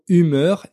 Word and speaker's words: humeur: Connaissez-vous la différence entre les mots humeur et humour Enfin, déjà, est-ce que vous entendez cette différence Humeur, humour. Humeur, ça s'écrit H-U-M-E-U-R et ça signifie l'état d'esprit dans lequel euh, vous êtes à un moment donné.